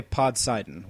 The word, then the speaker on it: Podsidon: I say Podsidon with a soft S, not a Z.